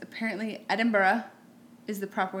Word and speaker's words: Edinburgh: And then how would you say? Edinburgh. – Edinburgh. – Well, Edinburgh. – Like Pittsburgh.